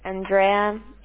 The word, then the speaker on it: Andrea: Andrea, A N D R E A.